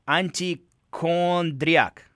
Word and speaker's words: Antichondriac: You just released a video for a new song called, I always have a hard time pronouncing it. Let me try. Antichondriac. – Yes.